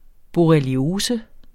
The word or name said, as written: borreliose